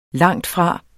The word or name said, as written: langtfra